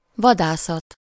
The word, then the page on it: chasse: /ʃas/